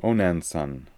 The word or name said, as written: Onanson